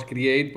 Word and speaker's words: Create: Se puede de las dos formas, pero vamos, decimos Create porque utilizamos la palabra en inglés.